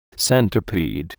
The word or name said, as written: centipede